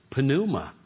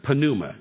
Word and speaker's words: pneuma: Pneuma. You say, well, you don't pronounce a P and an N together. Pneuma. It's like pneumatic, you don't pronounce the P. They sure do in Greek.